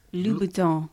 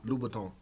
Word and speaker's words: Louboutin: Louis Vuitton. – No, you're getting it confused with Louis Vuitton. – Yeah. – Louboutin. – Louboutin.